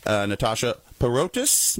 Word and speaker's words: Natasha Paroutis: We're happy to have Natasha Paroutis.